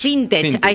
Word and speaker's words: fintech: La norma busca facilitar la renegociación de créditos de familias y empresas con entidades bancarias, financieras y Fitnet. ¿Se dice así? Sí, Fintech. Ahí Fintech. – está, me trabé.